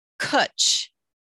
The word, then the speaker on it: cwtch: There's a word called cwtch. It rhymes with butch,